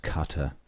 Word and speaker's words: Qatar: So Mike has said it's actually pronounced Qatar.